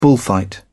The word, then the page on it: bullfight: British English